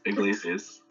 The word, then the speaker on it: Iglesias: Close, Iglesias.